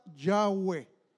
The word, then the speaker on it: Yahweh: Por lo que eventualmente la gente, al tener esas cuatro letras, lo pronunciaba Yahweh.